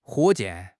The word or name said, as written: 火箭